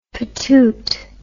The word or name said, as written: patoot